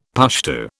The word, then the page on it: Pushtu: British English